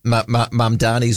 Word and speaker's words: Mamdani: The New York mayor, Mandami, and now I haven't said his name out loud before, so it might have an extra syllable in there. I should get it right. Let me just Google that up. Mamdani, Zoran Mamdani. Flip, you'd love this, Bob.